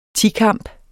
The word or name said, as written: tikamp